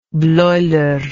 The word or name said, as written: bleuler